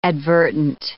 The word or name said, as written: advertent